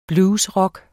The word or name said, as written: bluesrock